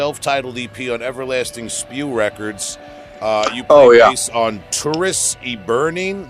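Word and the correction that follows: Turris Eburnea: Maybe you could pronounce it, I don't know. – Turris Eburnia. – Turris Eburnia, okay. – Because it's an NEA at the end.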